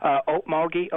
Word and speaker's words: Okmulgee: Okmulgee, Oklahoma. – Okay, Okmulgee.